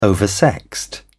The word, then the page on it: oversexed: British English